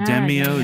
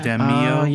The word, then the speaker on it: Demio: Yeah, a lot of people in chat are asking us to talk about Demio. Is that how you say it? – Yeah, Demio? – Yeah. – Demio? – Yeah. – I never knew how to pronounce that.